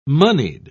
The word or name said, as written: moneyed